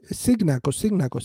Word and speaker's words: Cignacco: So Cignacco, C-I-G-N-A-C-C-O.